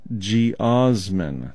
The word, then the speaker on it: geosmin: Okay, the word of the day is geosmin. Okay, here's how it's spelled right here. G-E-O-S-M-I-N.